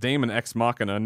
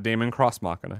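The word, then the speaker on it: Daemon X Machina: And then Lord of Pwn says, Daemon Ex Machina is actually pronounced Daemon Ex Machina, not Daemon Cross Machina, as Greg said earlier.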